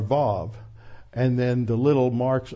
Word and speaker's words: Vav: But when you go to Israel, they all pronounce the Vav like a V. They don't pronounce it like a W.